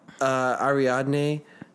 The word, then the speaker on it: Ariadne: Where he's like showing Ariadne. Am I say that right, Caleb? – Yeah, you got it.